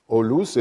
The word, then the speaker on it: Aulus: Olus était une forme, une prononciation vulgaire du prénom Aulus, parce que le Aou, à partir d'un certain moment, au début de notre ère, était prononcé dans les quartiers populaires olus.